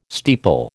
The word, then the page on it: steeple: American English